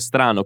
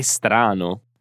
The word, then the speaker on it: strano: Infatti era molto strano che la connessione non funzionasse bene e perciò dice che strano. Attenzione anche alla pronuncia di questa parola. Strano. Strano. Non c'è nessun suono prima di str.